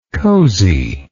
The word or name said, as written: cozey